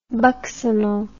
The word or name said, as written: buccinal